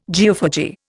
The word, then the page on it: geophagy: Indian